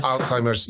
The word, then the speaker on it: Alzheimer's: Alzheimer's UK, which is very dear to your heart.